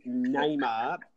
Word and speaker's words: Neymar: It's actually Neymar, and the Brazilians out there would be going absolutely bunter. I'm sure they listen to this show. They would be going absolutely bunter and probably would have you on their hit list for calling him Neymar, because it is Neymar.